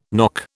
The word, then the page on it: nock: British English